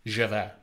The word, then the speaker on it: Javert: But is it not pronounced Javert? – Well, it's French. Javert. – Is this like one of them jalapeno situations? – No. It's a J. That's just the way the French pronounce J's.